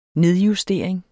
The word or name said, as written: nedjustering